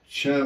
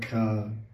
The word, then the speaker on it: Cha: So then it says, so the line says, the line means that Kranita means vibration, Tet means that, Tet always means that, Cha means end. You want to have your vocabulary word for it, Cha means end, C-A always means end. It's not, it's pronounced C-H, C-H-A, Cha, not Ka, it's Cha.